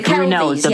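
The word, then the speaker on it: Kelvey: Am I saying it right? The Belvies? – The Kelvies, yeah.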